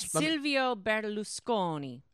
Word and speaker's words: Berlusconi: Silvio Berlusconi.